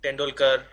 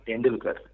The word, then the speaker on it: Tendulkar: So it's Tendulkar, not Tendulkar. It's a Tendulkar. – Tendulkar.